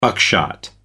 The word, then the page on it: buckshot: American English